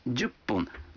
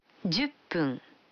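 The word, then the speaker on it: дюпун (十分): А 10 минут – дюпун. Вы заметили, что когда фун добавляется к 10, дю, то произносится пун. – Послушайте и повторите каждую фразу. 5 минут. – Гофун. – 10 минут. – Дюппун.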